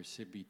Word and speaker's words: Sigve: And I have the privilege of introducing our speaker, Dr. Sigve Tonstad. It's pronounced Sigve. I've been calling him Sigve and Sigve and a number of things, but I've got the correct pronunciation now.